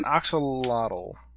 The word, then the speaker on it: axolotl: It's an axolotl.